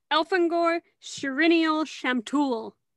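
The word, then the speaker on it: Elfangor-Sirinial-Shamtul: I don't know how to say his name. – Okay, let me try. Wait, let me try. Elfangor Shirinial Shamtul. – Shamtul. Okay, great.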